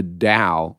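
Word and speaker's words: Tao: The Dow, which is spelled with a T, T-A-O, of Willie.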